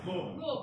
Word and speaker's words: Louisville: So we are bottled in Louisville, you say? – Yeah.